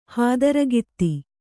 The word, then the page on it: ಹಾದರಗಿತ್ತಿ: hādaragitti